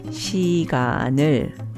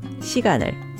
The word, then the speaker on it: 시간을: So we get 시간을, pronounced 시간을, 시간을.